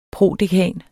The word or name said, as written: prodekan